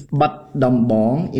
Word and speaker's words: Battambang: It's Battambang in Khmer. It's written Battambang, but the Khmer is Battambang.